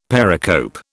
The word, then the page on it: pericope: British English